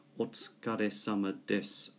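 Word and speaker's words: お疲れ様です: So there is a Japanese expression, otsukare-sama desu or otsukare-sama deshita.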